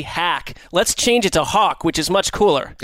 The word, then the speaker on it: Haack: I just don't see how H-A-A-C-K is Hawk. – It's not. I'm not suggesting that if you're just going to look at the way it's spelled, I wouldn't come to the same conclusion, but it confused me, so I went and looked it up, and I've been told that it's Hawk.